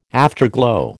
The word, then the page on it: afterglow: American English